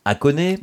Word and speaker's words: Hakone: Est-ce que je prononce bien ? Hakone ? – Oui, vous prononcez très bien.